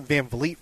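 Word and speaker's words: VanVleet: Call Fred Van Fleet. – He won a championship. You might want to get Van Vliet right.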